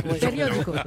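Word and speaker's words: periódico: Oui, sauf que ça, c'est périodico. – Périodico. – El journaux locaux. – Non, non, non. Voilà, périodico.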